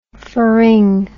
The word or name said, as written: pharyng